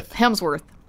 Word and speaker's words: Hemsworth: And in this metal world is at one of the Helmsworths. Is that how you say it? Helmsworth? Like, Miley Cyrus's? Not her husband, but a Hemsworth. Oh, yeah. – Same difference. It's a metal world with a Hemsworth.